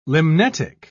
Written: limnetic

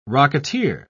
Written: rocketeer

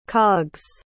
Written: cogs

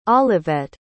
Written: Olivette

